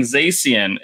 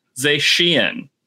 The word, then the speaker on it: Zacian: And I found out on that day that what we've been calling Zacian is actually pronounced Zacian. That CI is apparently doing some SHs work there.